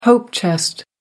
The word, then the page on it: hope chest: American English